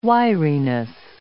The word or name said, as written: wiriness